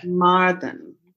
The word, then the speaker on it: Carmarthen: Did I say that right, Carmarthen? – Carmarthen. – Carmarthen. – The difference with the Welsh is that we tend to put our emphasis on different parts of the words that might seem obvious to English speakers.